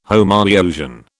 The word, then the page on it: Homoiousian: British English